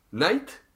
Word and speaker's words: knight: Field Field Knight, la traducción es caballero, pero en inglés se escribe con una K al principio que no se lee. Que no suena.